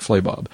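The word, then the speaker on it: Flabob: I'm pretty sure I'm pronouncing that right. Flaybob. – Flaybob, yeah.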